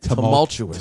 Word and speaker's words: tumultuous: Because, you know, I've been through a lot of, like, Tumultuous? Is that how you say it? – Tumultuous. – Tumultuous. – Tumultuous. – There's tumultuous. – No, where's the L's? Two L's in there? Tumultuous.